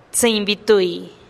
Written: tsïinn bi tui

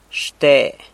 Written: xte

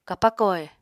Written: kapako he

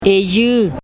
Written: eyüü

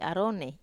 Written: aro

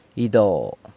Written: ydo

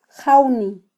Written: jawni

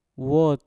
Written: wot

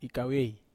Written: kavei

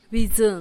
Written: bidzun

